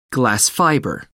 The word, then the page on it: glass fibre: American English